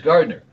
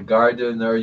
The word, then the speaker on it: Gardener: It's not James Gardner. It's Gardener, like the gardener you hire to take care of your garden.